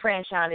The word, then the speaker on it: Franchon: I am Sweet, S-U-I-T-E. Sweet Franchon is my name, spelled like a hotel suite, but it sounds so sweet like candy, S-U-I-T-E-F-R-A-N-C-H-O-N.